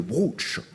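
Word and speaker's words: brocciu: Le brooch. Ça s'écrit brucio, si on veut. B-R-U-2-C-I-O. Mais je crois que communément, on dit le brooch.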